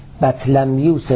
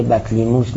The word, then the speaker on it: بطلمیوس: و المتأخرون المقتفون لارشاد بطلیموس الفاضل اثبتوا، متأخرونی که پیروی میکنند رصدهای بطلیموس فاضل را، بطلمیوس الفاضل، بطلمیوس درسته ولی بعضی بطلیموس مثل خود منم گاهی تلفظ میکنه.